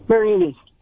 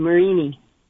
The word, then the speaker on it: Merenie: Marini. – I'm sorry. How do you pronounce it? – M-E-R-E-N-I-E. Marini.